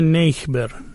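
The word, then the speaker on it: neighbor: یا نیبر که در انگلیسی میبینید دیکته سختی داره، N E I G H B لون، این اصلش بوده نیخبر، نیخبر. بعدن شده نیبه، ولی اونا دیگه تلفظ جدید رو میگن، تصمیم گرفتن شکل قدیمی رو دست نزنن.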